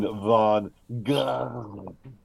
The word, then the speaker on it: Van Gogh: I believe Ken's trying to refer to Vincent Van Gogh.